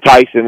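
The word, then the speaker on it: Taisun: I mean, he spelled his first name T-A-I-S-O-N, being Tyson, and then Famicon.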